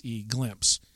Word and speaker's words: Glympse: And that app, rather, is called Glimpse. And it's spelled G-L-Y-M-P-S-E, Glimpse.